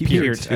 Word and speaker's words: Peart: It's Peart, as in pull your ear. Peart.